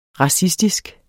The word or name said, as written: racistisk